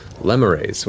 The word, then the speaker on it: lemure: though you're also seeing large groups of lemures, which is how it's pronounced, as I've been corrected. And I'm so glad it's pronounced that, not lemures, because it sounds not awful lemurs? In hell. It's lemure.